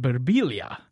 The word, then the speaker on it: Birbiglia: And he introduces himself and he says, he went to Italy and he's like, my name's Mike Birbiglia. And they're like, in Italy, you pronounce it Birbiglia. And he's like, well, in America, you're an idiot.